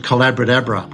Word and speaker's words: Collaboratabra: I also wrote a book called Collaboratabra, which rhymes with abracadabra.